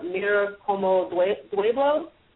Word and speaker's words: Mira Como Duelo: This is Mira Como Duelo. Am I pronouncing mm-hmm. – that correctly? Okay. – That's right.